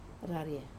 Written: raria

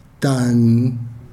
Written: tan

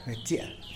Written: betia

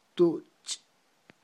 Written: tuch'

